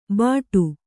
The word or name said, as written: ಬಟು